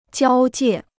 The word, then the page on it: 交界: jiāo jiè